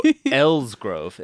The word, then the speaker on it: Elsgrove: So Elsgrove. It is spelled E-L-S-G-R-O-V-E. And that... Like it's in the name, a grove.